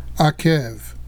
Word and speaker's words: Akev: Now there's another Hebrew word spelled the same way, with the Hebrew letters Ayin, Kof, Vet. It is pronounced Akev.